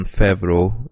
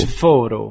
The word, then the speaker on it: Favreau: Jó, úgyhogy ez volt a büntető, és akkor a következő filmünk az a Séf 2014-ből, ami igazából egy ilyen John Favreau, úgy kell kiejteni az illető nevét, ugye? – Hát mondd azt, Favreau. és Favreau, Favreau és Favreau.